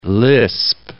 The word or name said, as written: lisp